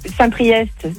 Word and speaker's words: Saint-Priest: T'as Saint-Priest, c'est EST, tu le prononces. Chaponneau, c'est ST, tu le prononces pas.